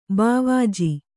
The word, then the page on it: ಭಾವಜ: bhāvaja